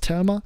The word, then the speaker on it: Thelma: der heißt Thelma oder Thelma, äh, wenn man es Englisch aussprechen würde, richtig ist Thelma.